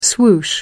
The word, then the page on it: swoosh: American English